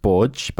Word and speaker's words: Borg: The most common surname in Malta is actually Borge. – Wait, what? – It's pronounced Borge, but it's spelled B-O-R-G. Borg.